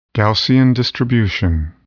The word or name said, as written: Gaussian distribution